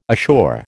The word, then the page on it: assure: American English